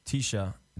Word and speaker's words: TSHA: I have this young producer named Tisha, it's spelled T-S-H-A, coming from the UK.